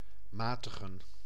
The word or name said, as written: moderate